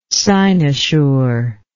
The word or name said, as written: cynosural